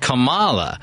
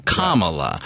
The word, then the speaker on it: Kamala: They said Kamala. Right. And so she's like, no, it's like the comma and then add a la, Kamala.